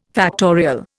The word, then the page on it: factorial: Indian